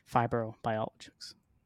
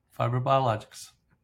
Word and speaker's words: FibroBiologics: Joining me today is the Chief Scientific Officer at FibroBiologics. Did I say that right? – Yes, you did. FibroBiologics.